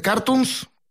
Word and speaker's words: Cartoons: Sí, bueno, las redes sociales de Cartoons, con acento en la U, de Cartoons, y en Facebook, Instagram, YouTube, Spotify.